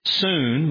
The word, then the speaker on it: syn: Synergism is from two Greek words, soon, which means together or with, S-Y-N, pronounced soon in Greek. We say sin, synergism.